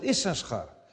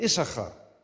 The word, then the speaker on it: Issachar: Wij zeiden vroeger altijd Issachar. En dat komt, er zit nog een extra S in. Maar die S kun je eigenlijk niet uitspreken. Issachar is een verkeerde uitspraak. Vandaar dat nu alle vertalingen ook zeggen Issachar.